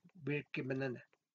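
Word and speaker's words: Birkebeinerne: Así se pronuncia en noruego, Birkebananda es un drama histórico noruego de 2016 dirigido por Niels Gaup.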